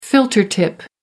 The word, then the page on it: filter tip: American English